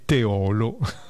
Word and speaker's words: Teolo: La pronuncia corretta di Teolo, comune in provincia di Padova, è Teolo,